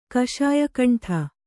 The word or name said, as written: ಕಷಾಯಕಂಠ